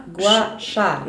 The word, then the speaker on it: Gua Sha: Beim Gua Sha ist es so, das heißt Gua Sha heißt Schlacke. – Ich hab's auch falsch gesagt, ne? – Egal. – Nochmal, Gua Sha.